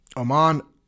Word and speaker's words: Oman: And many people, there's lots of jokes, oh man, because that's how it's spelled, O-M-A-N, oh man, Oman, Oman is what it's called.